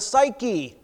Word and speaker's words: psyche: In the Greek, it's the word psyche, another silent P at the beginning.